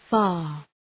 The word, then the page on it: far: fɑ:r